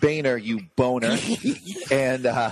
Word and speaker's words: Boehner: It's B-O-E. That's Bo. H-N-E-R. That's Ner. Boner. – And you spelled it B-O-N-E-R as well.